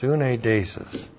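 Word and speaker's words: suneidesis: S-U-N-E-I-D, long E-S-I-S. Sunedesis.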